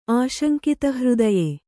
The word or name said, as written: ಆಶಂಕಿತಹೃದಯೆ